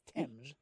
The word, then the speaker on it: Thames: Fun fact, the river spelled T-H-A-M-E-S is pronounced Thames.